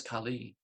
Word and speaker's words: Kali: And this is not the Kali Yuga of Kali, the goddess Kali. This is Kali, who is a demon, short A, short I, Kali.